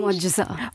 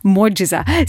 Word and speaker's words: Mojaza: Is that the correct pronunciation? – Mojaza. – Mojaza.